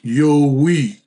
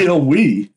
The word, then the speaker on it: Yowie: But she says, this is the funniest part, although the narrator pronounced it as Yowie. And she said, Yo-wee. – And she says, kind of like Stallone saying, yo, Adrian.